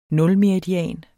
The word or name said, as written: nulmeridian